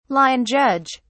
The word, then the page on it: line judge: British English